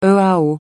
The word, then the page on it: EAO: francais